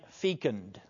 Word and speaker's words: fecund: The image of the vine and the branches is participative and fecund. Now, that's a word that may be new to you. F-E-C-U-N-D, pronounced fecund, which means fruitful.